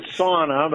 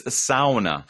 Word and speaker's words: sauna: And so, I pronounce it sauna, but I know. – Right. – But if you talk to a Finn, it's sauna.